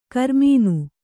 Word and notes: ಕರಿಮೀನು: karimīnu

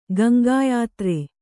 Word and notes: ಗಂಗಾಯಾತ್ರೆ: Gaŋgā yātre

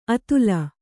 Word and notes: ಅತಲ: atala

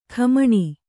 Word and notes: ಖಮಣಿ: Kannada

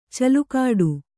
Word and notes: ಛಲಗೇಡಿ: kannada

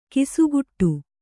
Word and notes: ಗಸಿಗಟ್ಟು: kannada

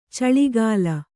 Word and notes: ಚಿಲುಕೂಲಿ: cilukūli

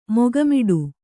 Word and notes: ಮೊಗಮೋಟ: moga mōṭa; kannada

